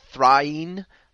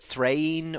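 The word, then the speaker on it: Thráin: I think what we came down is Thrain. I think the sort of emphasis on the second syllable, it's 2 syllables, and the emphasis on the second syllable is undisputed.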